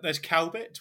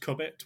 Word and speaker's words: Cowbit: There's Cowbit, which is pronounced Cubit, which makes no sense to me.